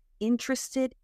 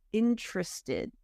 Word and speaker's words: interested: With this one, we eat one of the syllables for the first word. So it's not interest, but interested. And notice it's more of a schwa sound instead of tre, tra. The T, the first T sounds like a CH, interested in.